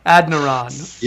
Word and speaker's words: Adeniran: Yeah, it's spelled ADENIRON. So it's like Adniron.